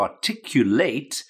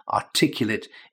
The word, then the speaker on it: articulate: Now, there is a verb to articulate, and when we're talking about the pronunciation, articulate is the adjective, a different pronunciation, different stress, okay?